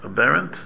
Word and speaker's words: aberrant: Aberrant behavior? Aberrant, that's how you pronounce it.